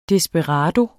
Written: desperado

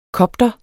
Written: kopter

